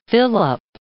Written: fill-up

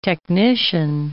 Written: technician

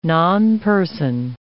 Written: nonperson

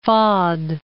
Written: Fahd

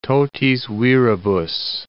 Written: totis viribus

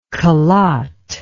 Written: kalat

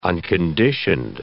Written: unconditioned

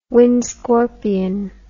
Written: wind scorpion

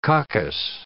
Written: coccus

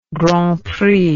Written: grand prix

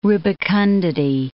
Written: rubicundity

